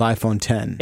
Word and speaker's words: iPhone X: Nope. It's called iPhone 10.